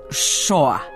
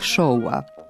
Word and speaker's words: šou: Ali ni dan danas nisam sigurna da li se kaže, na primer, zvezde ovog showa, kako sam čula u jednoj emisiji u kojoj se predstavljaju talenti, ili zvezde ovog showa. – Kaže se zvezde ovog šoua, mada je lakše izgovoriti zvezde šou programa.